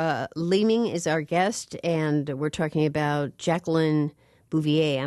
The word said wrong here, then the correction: Bouvier: I'm sorry, I was mispronouncing that. I don't speak French. Bouvier, Kennedy Onassis, The Untold Story.